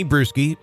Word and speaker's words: Brueski: It's under my name, at Tony Brewski, B-R-U-E-S-K-I is how you spell it.